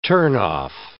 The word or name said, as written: turnoff